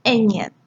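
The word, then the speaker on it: eñe: 下一个 N 上面有个波浪，这个是西文。就是最具代表的字，那它的发音是 a 年 a 年，就是比 n 多了一个鼻音 a 年。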